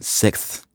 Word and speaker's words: sixth: But the word that actually gave me the most trouble was the word sixth, which I'm still having trouble pronouncing even now in this moment, because there's three consonants back to back, and I really want to give all of them their full pronunciation, but it's phonetically very, very difficult to do.